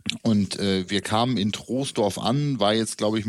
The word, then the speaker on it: Troisdorf: Das heißt, glaube ich, nicht Troisdorf, sondern tatsächlich Troisdorf.